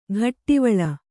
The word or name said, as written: ಘಟ್ಟಿವಳ